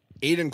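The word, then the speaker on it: Aidyn: So then he actually looked at the cartridge for the first time and he goes, dude, it's not Aiden Chronicles the First Mage. It's Aiden Chronicles the First Mage. It's A-I-D-Y-N.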